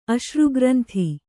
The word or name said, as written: ಅಶ್ರುಗ್ರಂಥಿ